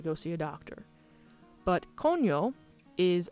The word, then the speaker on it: coño: The next word and final grammar lesson is coño, which is spelled C-O-N-O. The N has a tilde over it, which is the little squiggly line. And it changes not just the sound of the N from cono to coño,